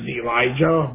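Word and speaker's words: Elige: No, actually, my real name is Elijah, but I go by Lodge.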